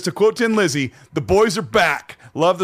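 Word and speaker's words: Thin Lizzy: Now, before I start this, because I just thought of this just now, I've been told most of my life that the official pronunciation of this band from Ireland is not Thin, but Tin Lizzy.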